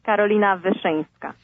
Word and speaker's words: Carolina Wyszyńska: My name is Carolina Wyszyńska. – And thank you, Carolina. Now everyone listening understands why I didn't try to pronounce it. It's Polish. Yes.